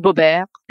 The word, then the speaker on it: Boebert: It's pronounced Boebert.